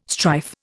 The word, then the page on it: strife: Indian